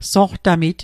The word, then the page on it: soqtamit: Mi'gmaq